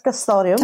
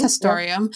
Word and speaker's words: Castoreum: They're very much desired for their pelts, but also Castoreum? Is that how you say it? I should look up how to pronounce that. – I think so. Castoreum. – Castoreum, yeah.